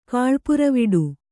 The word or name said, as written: ಕಾಳ್ಪುರವಿಡು